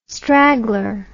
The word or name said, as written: straggler